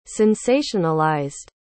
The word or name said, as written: sensationalized